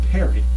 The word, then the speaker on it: Pierre: By the way, I didn't know until recently, speaking of our South Dakota knowledge here, the state capital is spelled P-I-E-R-R-E, It's not Pierre. I think they pronounce it Perry, I think. And if I'm wrong, let me know.